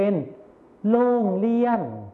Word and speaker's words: โรงเรียน: โรงเรียนนั่นเองครับนักเรียนลองอ่านออกเสียงพร้อมกันสิครับโรงเรียนอีกครั้งนะครับโรงเรียนมาต่อกันที่ภาษาไทยถิ่นอีสานกันเลยนะครับจะออกเสียงเป็นโล่งเลี่ยน